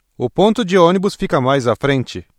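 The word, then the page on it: frente: Brazilian Portuguese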